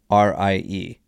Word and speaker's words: charcuterie: It's spelled CharCuterie. It's pronounced charcuterie.